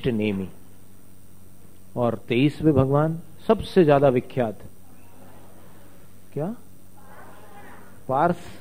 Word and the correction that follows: पार्श्व: स कैसा बोला आपने, पार्श्व, पार्श्वनाथ भगवान।